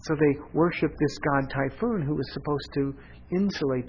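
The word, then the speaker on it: Typhon: Now, it's only spelled with one O, but it's pronounced Typhoon.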